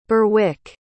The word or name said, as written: Berwyk